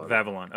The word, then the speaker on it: Vavilon: Are you pronouncing it Babylon or Vavilon with a V? – It's with the V. – Vavilon.